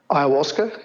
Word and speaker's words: ayahuasca: So the research is looking specifically at the psilocybin and how do I say it again? The ayahuasca. – Ayahuasca, yeah.